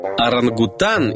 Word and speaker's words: орангутан: Следующее слово, где могут скрываться лишние буквы, это орангутан или орангутанг. С малайского языка название этой большой рыжей обезьяны звучит оранг-утан и переводится как «лесной человек».